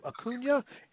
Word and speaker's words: Acuña: Acuña is ready to go.